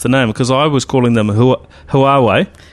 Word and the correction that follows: Huawei: So the easiest way is to just imagine it's spelled with a W instead of an H at the beginning and just say Huawei. But really, you can add a little bit of a H in there as well, Huawei.